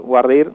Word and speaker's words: voir dire: tiene un nombre en inglés, digamos, Wardir, Voir dire, se escribe,